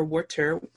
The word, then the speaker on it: water: Element is water, or water, depending on where you are on a planet. I'm from Philly, so I pronounce water as W-O-O-D-E-R.